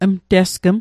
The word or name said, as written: mtesgm